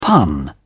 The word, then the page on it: pun: British English